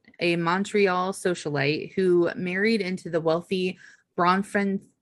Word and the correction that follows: Bronfman: No, you're adding like extra syllables. I think it's just Bronfman.